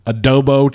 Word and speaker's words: adobo: I got myself a grilled adobo chicken burrito.